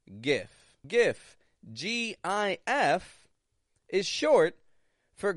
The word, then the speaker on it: GIF: That is GIF, not JIF. A lot of people will say JIF.